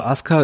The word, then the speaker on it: Asuka: Also Asuka ist natürlich die, die richtige Aussprache. Hm, das liegt daran, weil das U im Japanischen oft so ein, ein sogenannter Schwundvokal ist. Das heißt, wenn du vorher einen harten Konsonantenton hattest, dann wird das oft verschluckt.